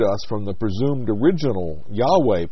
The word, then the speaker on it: Yahweh: The pronunciation can never be certain, since early Hebrew had no vowels, only consonants. Though evidence from several sources, such as early Greek transliterations, point to the pronunciation as Yahweh.